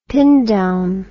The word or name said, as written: pin down